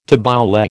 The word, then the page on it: tabbouleh: American English